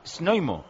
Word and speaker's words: Znojmo: Z-N-O-J-M-O. Snoimo. – Niomo. – Snoimo. – Oh, qué bueno. – Nomo. – Nomo está bien. – Pues Nomo está bien, dice el otro.